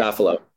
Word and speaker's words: Safilo: is it Safilo Group? Is that how you say it? – Safilo. – Safilo Group. Okay.